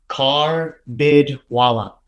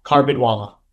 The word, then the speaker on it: Karbidwala: It seems complicated, but it's a little bit easier. It's phonetically Karbidwala. Karbidwala.